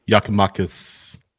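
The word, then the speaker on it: Giakoumakis: Yakimakis. – I got that one right. – That's how it's pronounced.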